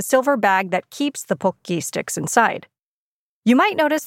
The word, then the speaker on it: Pocky: Think about the last time you might have eaten a box of pokki, or pocky, as it's pronounced in the West. Okay, pokki. The name pokki mimics the Japanese onomatopoetic word for snapping, pokki pokki.